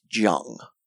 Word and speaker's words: Zhang Weili: I know, and look, Zhang Weili, however you want to say it, I've been told it's Jung. It's pronounced Jung.